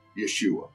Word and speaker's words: Yeshua: So Jesus would have been in his day, they would have called him in the Jewish pronunciation, Yeshua, okay, right.